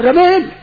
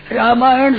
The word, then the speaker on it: रामायण: मान ये तो रमियन है, रमेन रमियान, रामायण शुद्ध उच्चारण नहीं कर सकते।